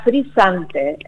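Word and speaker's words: FRZNTE: Se pronunciaría Frisante, así nos dijo en una de las reuniones.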